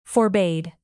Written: forbade

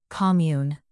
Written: commune

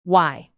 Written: why

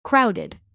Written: crowded

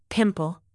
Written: pimple

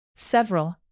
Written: several

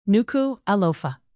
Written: nuku-alofa

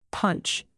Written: punch

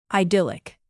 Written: idyllic

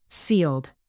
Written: sealed

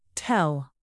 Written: tell

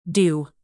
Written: dew

